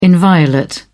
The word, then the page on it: inviolate: British English